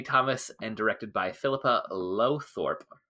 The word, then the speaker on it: Lowthorpe: And I trust I got close on that pronunciation. Uh, I'm just going to try and say it with more confidence. Philippa Low- Lowthorpe. There we go. That that sounded right.